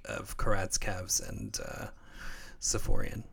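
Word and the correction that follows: Safiullin: Safulin. It's Safulin.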